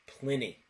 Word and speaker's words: Pliny: beer that we have reviewed in the past called Blank the Elder is actually not pronounced as we've been pronouncing it. It's actually pronounced Pliny.